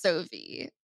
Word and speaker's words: Sovi: is, I believe, is pronounced Sovi. So the reason why I'm not sure is because SoFi is the student loan refinancing company, and it's S. This is S O V I. So I'm just assuming it's Sovi and not Sovi, but We'll go with it.